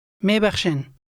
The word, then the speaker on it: می‌بخشین: Mebakhshin.